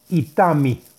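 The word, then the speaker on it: Itami (איטמי): Ahí trae un nombre sagrado para meditar y son las letras Aleph Yud, Tet Mem Yud y se pronuncia Itami.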